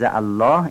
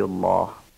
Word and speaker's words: الله: اما در این استعاذه یا در بسم الله یا در الحمدلله که در همه این عبارات قبل از الله کسره آمده، شما لفظ الله رو باید نازک و معمولی تلفظ کنید.